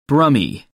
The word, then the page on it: brummie: American English